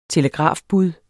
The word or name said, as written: telegrafbud